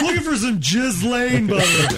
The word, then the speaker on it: Ghislaine: Ghislaine. – No, Ghislaine.